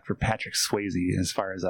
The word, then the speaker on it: Swayze: Yeah, Swayze rhymes with hazy.